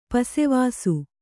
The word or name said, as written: ಪಸೆವಾಸು